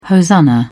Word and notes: hosanna: British English

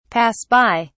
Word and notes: pass by: British English

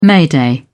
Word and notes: mayday: British English

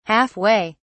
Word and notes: half way: British English

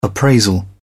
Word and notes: appraisal: British English

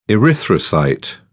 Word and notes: erythrocyte: British English

